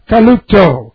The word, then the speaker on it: kalupto: Perfect, something in the past, passive, they receive it, of kalupto. And let's look at kalupto. And this is K-A-L-U-P-T long O. Kalupto.